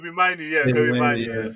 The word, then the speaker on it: Mainoo: Kobe Mainoo.